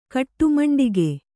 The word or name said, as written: ಕಟ್ಟುಮಂಡಿಗೆ